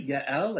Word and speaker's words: Jael: Yael? Like, that's your protagonist's name? It looks a little weird on paper. It's spelled J-A-E-L.